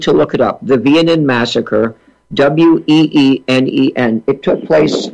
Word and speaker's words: Weenen: The assignment helped me with the pronunciation here of something that's spelled W-E-E-N-E-N. So it looks to us as Weenen. W-E-E-N-E-N. He helped me. The pronunciation, as I remember it, is Vienen. – That's correct. That's perfectly correct.